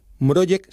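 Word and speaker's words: Mrożek: Mrojek se escribe Mrozek. Esa Z se lleva un puntito en polaco y es como Je.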